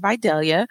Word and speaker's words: Videllia: Um, so my name, I go by Lady V, but my name is Videllia.